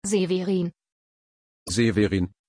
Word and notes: Severin: German

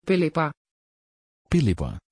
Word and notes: phillippa: Dutch

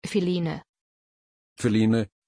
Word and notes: philine: German

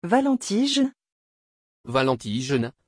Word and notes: valentijn: French

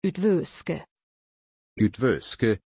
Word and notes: üdvöske: German

